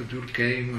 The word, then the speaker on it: Durkheim: Tanto Durkheim, no? Mauss, francesi e pronunciati alla francese, no?